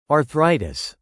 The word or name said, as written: arthritis